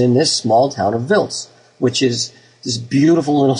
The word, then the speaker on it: Wiltz: I presume it's Wiltz and not Wiltz. – Wiltz. It's pronounced Wiltz, but you know sometimes the Americans call it Wiltz.